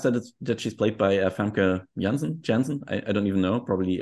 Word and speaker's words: Janssen: It would be Janssen. I mean, she's Dutch, so that's what I would say, of course. But we are German, so we would never think of pronouncing it in an English way.